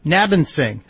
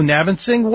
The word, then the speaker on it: Nabinsing: N-A-B-I-N. – Yeah, Nabinsing. Hello, Nabinsing.